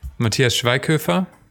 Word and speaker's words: Schweighöfer: Matthias Schweighöfer. Matthias is the Matthew of Germany. – Schweig. – And then S-C-H.